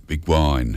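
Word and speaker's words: Beguine: We're going to move on to Artie Shaw's version of Begin the Begin, Begwine. Hard to pronounce that one, Begin the Begwine.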